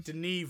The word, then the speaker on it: Denis: Denis Villeneuve. – This guy called Dennis. – He's not called Dennis, he's called Denis! Sorry.